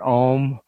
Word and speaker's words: Ogham: O-G-A-M or O-G-H-A-M, and you either pronounce it Om or you pronounce it Ogham.